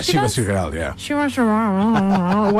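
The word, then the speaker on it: Chivas Regal: Yeah, Shivas Regal.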